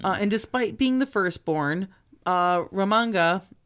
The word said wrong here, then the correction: Ramanga: Ramanga, I'm sorry, was upset that she was going to be overlooked for the crown because of her gender.